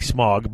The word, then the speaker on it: Smaug: S-M-A-U-G? – Smog. – Yeah, A-U is pronounced ow. – Really? – Yeah. Right. I mean, it could be ow, it's like smog, but if you listen to the books on tape and stuff, and actually a friend of mine got me one year a thing of Tolkien reading bits, excerpts, Tolkien pronounced it smog. – Smog. – So it's smog. – Okay, it's smog.